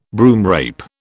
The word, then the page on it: broomrape: British English